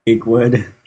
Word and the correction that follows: Achewood: Isn't it pronounced Achewood? – Achewood.